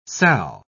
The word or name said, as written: sal